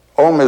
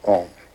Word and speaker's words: AUM: It's written A-U-M. You start in the back of the mouth, ah. And then ooh, you fill the mouth. And mm closes at the mouth. And when you have pronounced this properly, all vowel sounds are in that pronunciation.